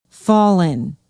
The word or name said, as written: Falun